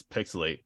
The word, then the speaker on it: PixelHate: This is Pixelate, where the H is silent and the hate is also real.